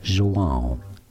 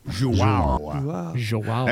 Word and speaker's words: João: João. – João.